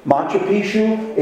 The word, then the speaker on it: Machu Picchu: Machu Picchu? – Machu Picchu.